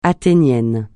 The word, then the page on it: athénien: francais